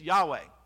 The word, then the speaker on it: Yahweh: but it's Yahweh. That is how we believe it is to be pronounced. Uh, YHWH is how you see it spelled out.